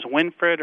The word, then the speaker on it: Winfred: His name was Winfred or Winfrith, depending on how you want to pronounce it.